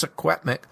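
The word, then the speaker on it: Secwepemc: It is also possible that the early traders first learned the Sequetmik language from Western bands who pronounced Sequetmik with a shoe sound.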